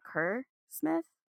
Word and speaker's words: Kerr Smith: Kerr Smith? – Yep.